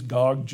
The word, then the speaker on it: Gog: You know, did they mispronounce it? It's supposed to be D, God? No, it is Gog, G-O-G.